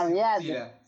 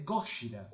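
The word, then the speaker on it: Gojira (ゴジラ): Y, no se dice Godzilla, se dice Gojira.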